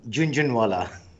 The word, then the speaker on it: Jhunjhunwala: Okay, it's like the month of June, Jhunjhunwala.